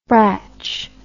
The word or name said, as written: brach